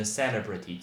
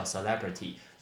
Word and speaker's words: celebrity: 那再来，我们刚说到那个明星，明星，比如说你喜欢的超级明星，我们不会说 superstar，我们可以说一个字叫 celebrity，来叫 celebrity，这个字超级多人会念成 celebrity，错，重音在 celebrity，celebrity，重音在 l e，叫 celebrity。